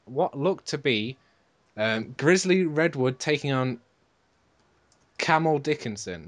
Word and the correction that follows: Kamau: It was Camel, wasn't it? It was not Camel. – I think it was Kamau.